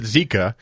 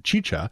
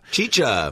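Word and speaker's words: Xicha: You mentioned it as a place that you had never heard of that made a Goza for the OBF, and you called it Zika because it's X-I-C-H-A. Now, on the side of the bottle, it tells you it's pronounced Chicha. – Chicha.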